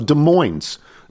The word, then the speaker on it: Des Moines: Hello from Des Moines, Washington. No, not the city in Iowa. We're south of Seattle, and we pronounce the S here. So Des Moines. Des Moines, Washington.